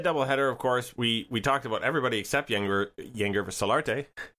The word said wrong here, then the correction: Yangervis: Yen Hervis. – Yen Hervis. Okay, why is the G pronounced like an H?